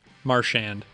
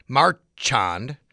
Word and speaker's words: Marchand: How do you say it? – Marchand. – You say Marchand? Marchand.